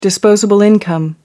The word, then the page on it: disposable income: American English